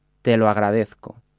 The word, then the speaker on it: te lo agradezco: A commonly used phrase in Spanish using the verb above is I thank you for it, which in Spanish is te lo agradezco.